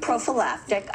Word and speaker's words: prophylactic: Which is a great prophylactic. I love that word. How do you say it? Prophylactic? Prophylactic. – Yeah.